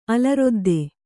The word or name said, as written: ಅಲರೊದ್ದೆ